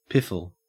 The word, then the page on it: piffle: Australian English